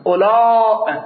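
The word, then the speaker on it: أولو: اونجا ما عرض کردیم آقایون میگن واو نوشته میشود اما خوانده نمیشود. اولا تلفظ میشه.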